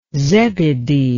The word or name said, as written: zebedee